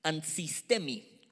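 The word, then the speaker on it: Ansistemi (ἀνθίστημι): Resistirse viene del griego ansistemi. Andisi. Ansistemi, con acento en la primera I.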